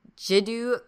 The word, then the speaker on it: Jiddu: Judo, I'm going to say his name horrible, Jiddu Krishnamurti.